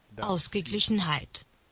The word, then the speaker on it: Ausgeglichenheit: And let me get a good pronunciation on that. This comes from Dict. – Ausgeglichenheit. – Ausgeglichenheit.